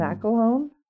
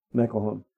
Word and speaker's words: McElhone: How do you say Mackle? Mm-hmm. McElhone. – McElhone.